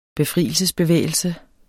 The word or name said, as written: befrielsesbevægelse